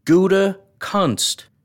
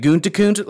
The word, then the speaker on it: Gutekunst: Everybody's sliding an N after the G-U-T-E. It's G-U-T-E-K. Gutekunst. And it's, what's his name? The guy that Rodgers talks to on Tuesday, the punter, McAfee. He puts the N, I think, before the T. Gutekunst. I don't understand how people struggle to just say things phonetically. What is it? Guntakunt? Like, just sound it out, dude. Look, just stop. Look at the word. There's one N in his name.